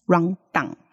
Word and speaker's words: rundown: 对，如果你只停在啊的话，那就会变成就是有点台式腔 run down。对，很像那个注音符号的 on，有没有？